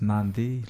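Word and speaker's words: Nadi: But it's pronounced as Nandi. Like if like sounds like there's an N sort of like an N in front of the D.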